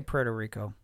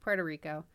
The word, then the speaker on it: Puerto Rico: Originally from Puerto Rico... Did I say that right? Did I say that super white? – Well, you could say Puerto Rico.